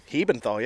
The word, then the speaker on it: Hebenthal: I want to introduce you um, John, how do you say your last name? Hebenthal? Hebenthal, yeah. You yeah. did it correctly the first try.